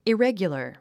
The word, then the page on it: irregular: iréɡjulə r; イレギュラァ